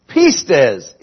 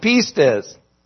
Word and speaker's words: pistis: The Greek word for faith is pistes. Pistes. It's spelled P-I-S-T-I-S, but it's the long E sound, pistes.